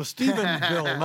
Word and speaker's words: Stephenville: And now, Zorba, a listener with us in Stephensville, Texas. Hi. – Hi, there's only one S in Stephensville. – Oh, Stephenville, not Stephensville. Stephenville, I'm sorry.